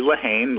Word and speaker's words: Lahane: Good morning, Marie, and my last name is actually Lahane, like pretty much like it's spelled.